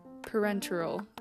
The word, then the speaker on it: parenteral: I literally just looked up how to pronounce this. Parenteral. There we go.